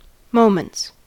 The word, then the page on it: moments: American English